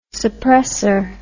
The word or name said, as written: suppresser